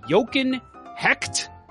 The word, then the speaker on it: Jochen Hecht: So July 8th, 1995, St. Louis Blues draft Jochen Hecht is how you say it.